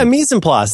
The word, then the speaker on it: mise en place: And you've talked so much about like food preparation and mise en place, if I'm saying that right. And yeah, mise en place.